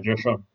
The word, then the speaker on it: Schoen: That's why GMs like Joe Schoen, that's how you pronounce it? – Yeah, Joe Schoen.